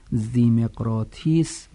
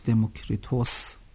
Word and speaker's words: دموکریتوس: یک دیدگاه، دیدگاه زیمقراطیس و پیروان او هستش، دموکریتوس. تلفظ عربی دموکریتوس زیمقراطیس هستش.